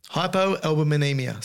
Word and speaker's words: hypoalbuminemia: Hypoalbuminemia. Say it. – I'm not going to try. – Okay, well, just say it three times. No, just for the listener. – We're at... – Okay. Hypoalbuminemia is a condition where you don't have enough albumin in the blood.